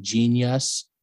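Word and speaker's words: jeen-yuhs: It's called like, it's spelled JEEN- like YUNS. It's like genius.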